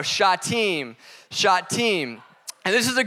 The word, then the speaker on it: Shittim: First off, that word is pronounced Shittim. Learn that this week. I've been saying it wrong my whole life.